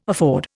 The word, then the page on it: afford: Indian